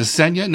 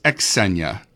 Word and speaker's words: Xenia: Is it Xenia? No, it's Xenia. I found out I'd been saying it wrong.